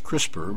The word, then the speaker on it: CRISPR: Incidentally, for the audience that don't know, it's a capital C, capital R, capital I, capital S, capital P, capital R, pronounced CRISPR, but it all stands for a big, long name, and that we just call it CRISPR.